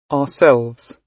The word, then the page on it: ourselves: British English